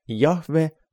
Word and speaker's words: Jahve: Sõna Issand koosneb heebrea keeles 4 konsonandist, jot, ha, kaksik, v, ha, mida hääldatakse Jahve või, nagu eestlased on vanastajast harjunud, Jehova.